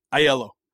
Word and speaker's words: Ielo: Ielo. – Ielo. Yep. I was kind of close. That wasn't bad. – Close. You almost sound out every letter, I-E-L-O, Ielo.